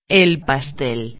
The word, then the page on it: el pastel: espanhol